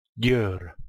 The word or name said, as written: Gyor